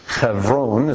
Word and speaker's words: Hebron: Hebron is how it's pronounced in Hebrew.